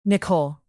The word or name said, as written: nicole